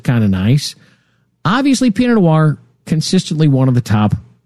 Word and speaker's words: Noir: Like, no, no, no, it's Noir.